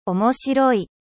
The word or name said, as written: drole